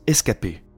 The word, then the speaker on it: escapé: En picard, échapper se prononce escapé.